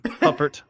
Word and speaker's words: Huppert: This is Belle Hubert, you know, Huppert. – Thank you for pronouncing it right the first time.